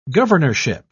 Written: governorship

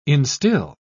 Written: instill

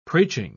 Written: preaching

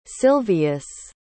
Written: Silvius

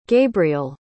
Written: gabriell

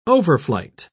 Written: overflight

